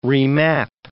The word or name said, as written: remap